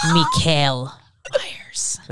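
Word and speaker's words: Michael Myers: Everyone's putting the emphasis on the wrong syllable. It's Mikael Myers.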